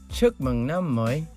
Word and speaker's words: Chúc mừng năm mới: Do you remember how I taught you how to say it? – Chúc mừng năm mới. – Nice. So chúc mừng năm mới to everyone out there.